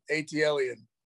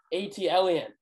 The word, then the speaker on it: ATLEN: ATLEN. – ATLEN. – Yeah. Okay. – That's how you pronounce it.